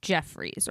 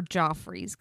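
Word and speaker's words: Geoffrey's: On September 16th, 2009, 24-year-old Mitrice drove to a restaurant called Jeffrey's, or Joffrey's, because it's spelled G-E-O-F.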